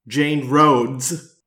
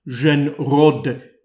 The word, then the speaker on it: Jeanne Rhodes: One exception is the Falcon, I would call her, Jane Rhodes, as Americans would pronounce it, Jeanne Rhodes, as the French would say,